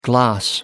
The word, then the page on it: gloss: American English